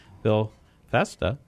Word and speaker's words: Bill Festa: It reminds me of the Fiesta Bowl, but it's Festa.